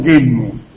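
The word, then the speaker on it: Gitmo: En inglés pronuncian gizmo. – Es gizmo.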